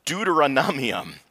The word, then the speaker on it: Deuteronomium: Deuteronomium. There we go. I had to get the accent on the right syllable. Deuteronomium, which we get Deuteronomy, which is still a mouthful to say, but at least a little easier than the Latin.